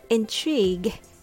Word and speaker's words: intrigue: So the word intrigue is spelled with G U E at the end, but U and E are not pronounced. So it's intrigue, intrigue.